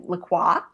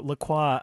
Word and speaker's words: LaCroix: It's the beverage is spelled LaCroix. So LaCroix, I guess, would be a more accurate French pronunciation.